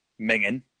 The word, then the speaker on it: minging: And it says, certainly common in Eastern Scotland where the final G isn't pronounced, minging.